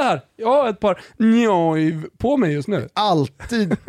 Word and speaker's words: Neuw: N-E-U-W, alltså Neuv blir det på tyska, så det är det som stör mitt österrikiska påbrå här.